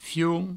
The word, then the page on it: fion: fioun; Provençal